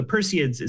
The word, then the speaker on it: Perseids: You're going to have to pronounce it for me. It's from Perseus, right? – Yeah, Perseids is what they're Perseids. – Called. – The Perseids.